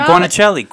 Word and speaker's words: guanciale: Gouda, fontina, mozzarella, parm, asiago, and a creamy bechamel with saffron roasted cauliflower topped with, I'm sorry, how do you say that word? – Cured guanciale. – Yeah, guanciale. Guanciale. – Guanciale. – Guanciale. It's Italian.